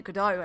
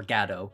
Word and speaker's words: Godot: I'm interested because you say it Godot, and I did not know there were two ways of pronouncing it. I'm the Beckett. – There's the Beckett way. Well, I mean, it might be either. We've never actually heard them say Godot or Gado. I don't know. Everyone says Waiting for Godot here, unless they're in theater, and then they kind of put an emphasis on the ga, and then it just sounds a little bit pretentious.